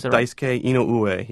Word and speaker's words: Daisuke Inoue: It's Daisuke Inoue, yeah.